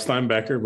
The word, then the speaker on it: Steinbaecher: It's close enough, Steinbaecher, but we're good.